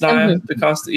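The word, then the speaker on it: Nia DaCosta: It's Naya DaCosta, yeah.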